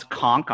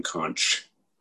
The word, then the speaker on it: conch: So the question is, did you purposefully mispronounce the word conk, as in conch shell? – Well, I was simply following the pronunciation rules for Italian, Joe, in which the CH would come out as a hard consonant. That would be conk. But I suppose that I should be saying conch or something like that.